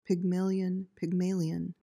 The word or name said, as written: pygmalion